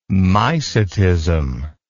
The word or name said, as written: mycetism